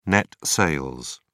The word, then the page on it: net sales: British English